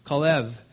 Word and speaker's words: Caleb: And by the way, it was pronounced Kalev is the pronunciation for Caleb.